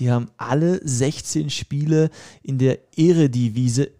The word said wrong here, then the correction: Eredivisie: ich hoffe, ich habe es richtig ausgesprochen, Eredivisie.